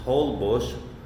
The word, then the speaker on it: Holbox: It's like 50, 60 miles away from Cancun, called the Holbox, pronounced Holbush, kind of like Yogi Obis's mom, Holbush.